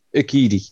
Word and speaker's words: Urquidy: I think he's a better pitcher than how do you pronounce this guy's name? Urquidy? – Yeah, that'll do. Urquidy.